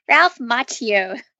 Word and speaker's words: Ralph Macchio: It was also, as Kelly mentioned before the show started, Ralph Macchiato's? How do you pronounce his name? – Ralph Macchio.